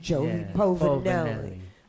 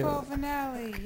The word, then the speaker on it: Povinelli: Joey Povinelli. – You have to say it like you've just eaten a plate of pasta.